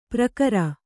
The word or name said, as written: ಪರಿಕರ